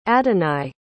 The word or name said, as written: addaneye